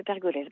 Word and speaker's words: Pergolèse: c'est bien le Stabat Mater de Pergolèse. Bravo pour la prononciation.